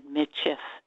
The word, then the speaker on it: Michif: And I didn't say Métis, which is up in Canada. I said Michif, and that's the correct way to say it here.